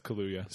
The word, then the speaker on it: Kaluuya: Oh, yeah, it is Kaluuya.